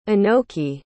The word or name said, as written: anoki